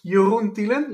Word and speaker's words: Jeroen Tielen: Yeah, so my name is Jeroen Tielen. You were pronouncing it very well for an English guy.